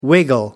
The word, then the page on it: wiggle: American English